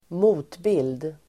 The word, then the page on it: motbild: [²m'o:tbil:d]